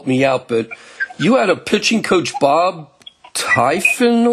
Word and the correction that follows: Tiefenauer: or I mean, I had his baseball card, but I don't think I don't think I ever was able to pronounce his name correctly. Bob Bob Tiefenauer. Tiefenauer, okay.